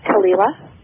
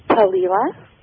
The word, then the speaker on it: Thalila: Talila. – Say it again. – Talila. – How do you spell that? – T-H-A-L-I-L-A. – Talila. – Mm-hmm. – Is that, am I saying that correctly? – Yeah.